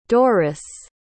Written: dorris

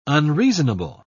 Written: unreasonable

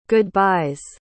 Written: goodbyes

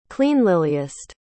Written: cleanlyliest

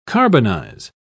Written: carbonize

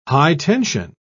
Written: high-tension

